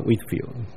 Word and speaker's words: Whitefield: 几年前有一次我跟一个英国来的宣教士讲话，我发现他提到这个人的名字跟我的读法不太一样，我就问他，因为我们看到这个字都会读 George Whitefield，所以中文都翻译成怀特斐，可是他说那个字不这样读，他们平常读那个名字读作 George Whitefield，读作 Whitefield。